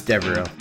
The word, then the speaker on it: Devereaux: It's Devereaux. – Devereaux? – Devereaux. – Devereaux. – There is no N in the name.